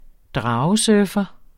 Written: dragesurfer

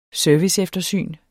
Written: serviceeftersyn

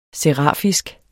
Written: serafisk

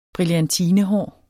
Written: brillantine

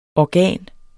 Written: organ

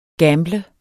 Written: GAMBLET

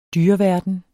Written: dyreverden

